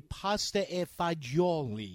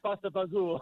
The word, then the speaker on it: pasta e fagioli: The Roman Italian would be pasta e fagioli. Yes. – So nobody say pasta fagioli. – Yeah, nobody talks like that.